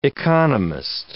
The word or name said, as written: economist